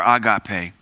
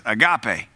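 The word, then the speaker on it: agape (ἀγάπη): The God kind of love is agapeo, or agape. We pronounce it here in America as agape, but it's agape and agapeo.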